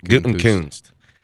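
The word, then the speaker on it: Gutekunst: Gutenkunst. How do you say it? – I think you got it. – Gutenkunst.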